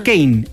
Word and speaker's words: Keyne: Es que se escribe K-E-Y-N-E. Ah, pero en la casa le dicen Kane, el hermano de Lamine Yamal.